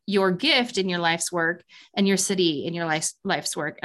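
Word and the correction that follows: Siddhi: And I always say that wrong, but it's SIDHI in your life's work.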